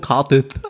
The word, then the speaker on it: Cardiff: From Cardiff. – Da kommt kein TH Cardiff, schreibt man mit F.